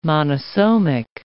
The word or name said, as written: monosomic